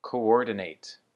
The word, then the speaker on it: coordinate: And for our final word today, it is coordinate. Coordinate. So once again, you can kind of say coordinate or coordinate. Same thing with the emphasis on the second syllable.